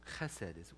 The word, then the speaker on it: Chesed: Chesed is the way to pronounce it.